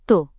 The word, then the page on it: tuh: indonesian